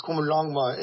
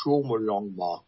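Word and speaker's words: Qomolangma: Komanglangma is, and it's sometimes pronounced Chulmulangma, is the Tibetan name for Everest.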